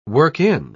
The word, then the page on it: work-in: British English